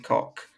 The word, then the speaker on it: Koch: No, it's Koch. K-O-C-H.